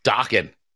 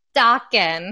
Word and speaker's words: Dokken: Is it Dokken? – Dokken. – Dokken.